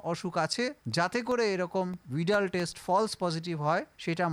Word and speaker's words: Widal: এনারা একটা পরীক্ষা করেন, যে পরীক্ষাটাকে উইডাল পরীক্ষা বলে, অনেকে উচ্চারণ ভুল করে এটাকে ওয়াইডাল পরীক্ষাও বলে, WIDAL, কিন্তু উচ্চারণটা ভিডাল টেস্ট।